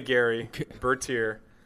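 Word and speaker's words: Gerry Bertier: Gary. Gary what Bertier. I say, Jerry? Oh, yeah. Gary. – Well, not right. Jerry Lewis. It is definitely Gary Bertier.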